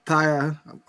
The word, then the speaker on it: Tia: Tia or Taya. Taya. I'll say Taya. That sounds dope. Tia is like aunt in Spanish.